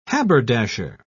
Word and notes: haberdasher: British English

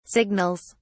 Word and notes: signals: British English

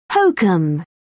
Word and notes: hokum: British English

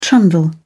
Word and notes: trundle: British English

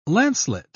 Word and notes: lancelet: British English

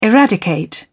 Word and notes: eradicate: British English

